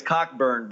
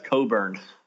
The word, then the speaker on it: Cockburn: With him and the other guy, with, you know, it looks like it's Cockburn, but I think they pronounce it as Coburn.